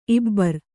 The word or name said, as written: ಇಬ್ಬರ್